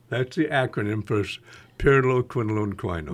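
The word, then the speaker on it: pyrroloquinoline quinone: Pyroloquinolone quinone.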